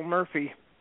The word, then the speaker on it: Murphey: But my website, just Google my name, Cecil Murphy.